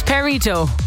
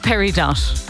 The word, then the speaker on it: Peridot: It's Peridot. It's not Peridot.